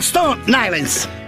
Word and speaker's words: Stone Island: Questi gym della Stone Islands.